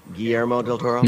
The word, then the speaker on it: Guillermo del Toro: And I probably pronounced that wrong. Guillermo del Toro. – There you go.